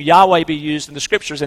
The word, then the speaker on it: Yahweh: Some people will pronounce it Jehovah. You've probably heard that. Better pronunciation of that in the Hebrew is Yahweh.